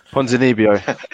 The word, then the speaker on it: Ponzinibbio: Santiago Ponzinibbio. – Ponzinibbio.